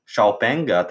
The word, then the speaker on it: Xiaopeng: No šiem visinteresantākais varētu būt Šaupenga, trīs uh, latviešu varētu izlasīt kā Xpeng. Uh, man liekas, ka to izrunā Šaupeng.